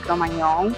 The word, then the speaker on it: Cro-Magnon: Cro-Magnon, se pronuncia, no es con ñ, es con gm,